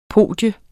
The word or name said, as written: podium